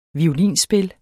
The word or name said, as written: violinspil